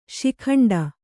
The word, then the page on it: ಶಿಖಂಡಿ: śikhaṇḍi